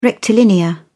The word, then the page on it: rectilinear: British English